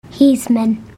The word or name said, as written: hismen